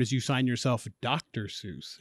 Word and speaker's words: Seuss: Seuss is actually pronounced Zeuss in German. But Seuss said that he liked that people thought of it as Seuss because it put him close to Mother Goose. So when you're saying Seuss, you're saying it correctly. But the German pronunciation is actually Zeuss.